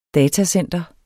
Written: datacenter

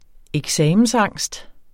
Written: eksamensangst

